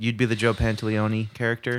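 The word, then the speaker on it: Pantoliano: Joe Pantaleone. – Pantaleone.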